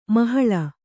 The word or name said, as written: ಮೊಹಲ್ಲಾ